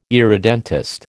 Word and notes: irredentist: American English

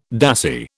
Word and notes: dassie: British English

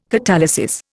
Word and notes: catalysis: Indian

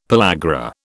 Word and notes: pellagra: British English

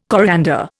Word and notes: coriander: Indian

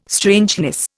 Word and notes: strangeness: Indian

